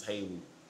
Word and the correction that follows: Halle: Halle Bailey.